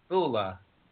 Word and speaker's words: Bula: How do you spell it? – B-U-L-A. Bula. – Dula. – B for Dula. – Bob. B for Bob. – Bula. Okay, Bula.